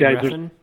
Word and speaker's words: Refn: Renf? How do you say that? Nicholas Renf? – Oh, Nicholas like Winding Refn? Yeah.